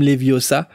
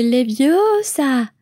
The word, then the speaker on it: Leviosa: C'est Leviosa et pas Leviosa.